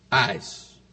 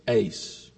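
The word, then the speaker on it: eis (εἰς): but I say it this way, eis. When it's transliterated into English, it's E-I-S. Most, I'll admit, pronounce it ace.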